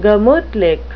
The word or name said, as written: gemutlich